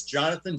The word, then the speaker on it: Jonathon: So it's so my my name is Jonathon J O N A T H O N.